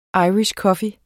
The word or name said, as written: irish coffee